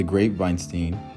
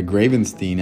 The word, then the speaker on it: Gravenstein: Jobs had gone for another visit to the All One Farm, where he had been pruning the Gravenstein apple trees. I said it wrong. The Gravenstein apple trees.